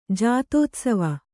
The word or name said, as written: ಜಾತೋತ್ಸವ